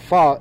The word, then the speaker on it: pho: Pronounced P-H-U-H, not P-H-O, like it's spelled.